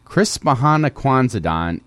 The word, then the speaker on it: Christmahanukwanzadan: Chris Mahan Akwanzadon is the annual interfaith celebration of all winter holidays.